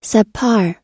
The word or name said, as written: subpar